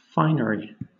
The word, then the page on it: finery: British English